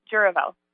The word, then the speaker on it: Jouravel: Well, last name is pronounced Jouravel.